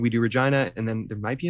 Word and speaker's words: Regina: I think it's Regina. – It's Regina. – I think it's the more stupid way to pronounce it.